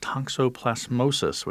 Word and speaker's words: toxoplasmosis: Residents say they're worried about their kids getting bit or sick from something like toxoplasmosis, which I'm impressed that I can say, and hopefully I pronounced it correctly. – You did.